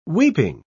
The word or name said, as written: weeping